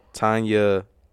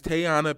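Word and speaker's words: Teyonah: It's Tayana Paris. – That's not no fucking Tayana. – Anna. That is Tayana. – Yeah. Wait. Tiana. – That is either Tayana or Tiana Paris.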